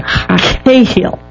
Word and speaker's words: hayil: Well, she brings up the Hebrew word, and it's actually spelled in English H-A-Y-I-L, but the H is a guttural sound like an axe. So it's kind of like a Cahill. – Okay. – Kind of is the pronunciation of it.